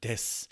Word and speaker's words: desu: When you say desu at the end, the predicate, if you will, at the end of a Japanese sentence is not pronounced desu, even though it's the su sound, the u at the end of this word is going to be cut off and it's just going to be s.